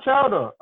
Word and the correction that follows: Chioda: I don't know how to pronounce his name. You're that veteran referee. – Oh, Mike, what's his name? Mike Chioda? – Yeah, Mike.